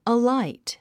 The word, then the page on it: alight: əláit; アライト